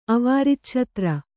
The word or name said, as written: ಅವಾರಿಚ್ಛತ್ರ